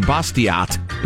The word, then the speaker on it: Bastiat: Ben the Libertarian reminds me that because he is French, the last T in Bastiat is silent.